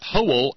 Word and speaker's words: Howell: Steve notes that Howell has two syllables just like dipshit has two syllables.